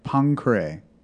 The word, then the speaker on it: Poincaré: Poincaré. – Okay, I'm sorry. I mispronounced the name. – Oh, no, I didn't know. You did. I thought you said Poincaré. – Oh. – I've always heard it a couple times, Poincaré. – Yeah. I mean, you do have... – It has an accent on the E.